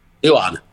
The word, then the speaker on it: Seoane: Seoane.